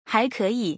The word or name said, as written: 还可以